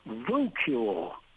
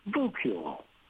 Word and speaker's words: voucule: This brand new word is voucule. Here's how it's pronounced. Vu rhymes with boo. Cule rhymes with mule. Voucule.